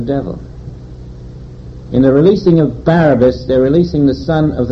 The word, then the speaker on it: Barabbas: And its correct pronunciation is Barabbas, son of the devil father.